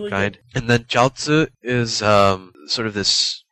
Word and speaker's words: jiaozi: Um, so jiaozi is the Chinese pronunciation.